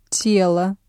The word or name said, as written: тело